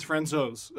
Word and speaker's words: Francouz: Pavel Francouz, which is the fourth pronunciation of his name. – Yeah, because we said Francouz for like a week now. – And I said Francouz before that. Apparently it's Francouz.